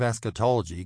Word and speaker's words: eschatology: It is pronounced eschatology. The doctrine of eschatology can be divided into two distinct categories.